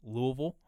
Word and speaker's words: Louisville: Louisville. Uh, you pronounce it wrong. It's Louisville. Louisville.